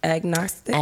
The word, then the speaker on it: agnostic: And how you pronounce it again? – Agnostic. – Agnostic? – Agnostic. – Agnostic. – Ag like Agnes.